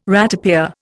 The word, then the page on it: ratepayer: Indian